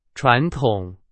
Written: 传统

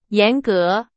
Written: 严格

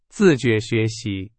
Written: 自觉

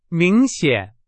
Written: 明显

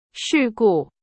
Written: 事故

